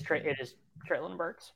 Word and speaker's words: Treylon Burks: Does he rhyme with Schmalen Kirks? – Yes. Yeah. It is Treylon Burks.